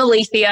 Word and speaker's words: Alethea: I'm Alethea.